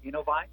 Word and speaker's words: Innovyze: So, a couple of people going, what's Innovyze? Right? Is that how you say it, first of all? Is it Innovyze? – Innovyze, yes, sir. That's the correct pronunciation.